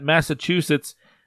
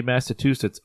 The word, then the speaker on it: Massachusetts: I've got Massachusetts beating Harvard, and again, I apologize to everybody that's hearing this and hearing me say Massachusetts over and over. It's not Massachusetts. It's Massachusetts.